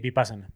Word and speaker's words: Vipassana: Sí, Vipassana.